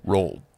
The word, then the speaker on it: Roald: Ronald Dahl actually hated, or Roald Dahl. Roald Dahl. How do you say that for his name? – Roald. – Roald. I always thought it was Ronald until like fucking 3 years ago.